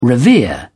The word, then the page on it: revere: British English